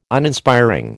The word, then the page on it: Uninspiring: American English